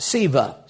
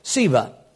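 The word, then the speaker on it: Sceva: And there were seven sons of one, and it's pronounced Siva. C is silent. Siva.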